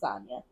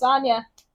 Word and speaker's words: Anya: And I was like, it's Anya. Even my therapist pronounces my name wrong sometimes. – What? Oof. – She calls me Anna instead of Anya. And I'm like, it's Anya.